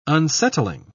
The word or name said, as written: unsettling